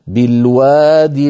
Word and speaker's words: بالواد: فإن وقفنا على الأولى التي كتبت من غير ياء على نية الوصل نقف كالخط، أي نقول بالواد، وأعود فأقول كل هذا اضطراري أو اختباري.